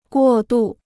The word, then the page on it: 过渡: guò dù